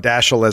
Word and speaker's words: Dashiell: I was glad we were able to land on Dashiell as the official pronunciation, no matter what anybody says.